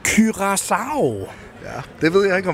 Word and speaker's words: Curaçao: Det er sådan, Andreas Kravl udtaler det, og om nogen må gøre det rigtigt, så må det være ham. Jeg har hørt ham sige Curaçao i fodboldlisten, så det må være rigtigt.